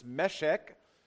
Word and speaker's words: Meshech: I've already said it, but the way you pronounce these two cities on the third paragraph, it's Meshech, so the accent is on the first E, and Kedar, the accent is on the first E.